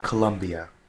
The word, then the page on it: Colombia: English